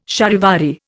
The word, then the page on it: charivari: Indian